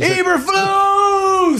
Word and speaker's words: Eberflus: Eberflus!